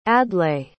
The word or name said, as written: adlai